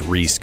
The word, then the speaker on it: Rhys: It's by a guy named Gruff Reese, which I think is spelled R-H-Y-S. Looks like Rise. I think it's pronounced Reese because it's Welsh.